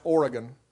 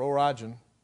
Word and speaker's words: Origen: It's spelled Origen. I don't think it's pronounced that way. I think it's pronounced Oregon. Oregon or Origen.